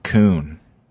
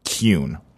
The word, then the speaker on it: Kuhn: The way that the spelling of the pronunciation is spelled out here, it does look like it's Bowie Kuhn, almost. – Kuhn, right? – The pronunciation is spelled K-J-U-N.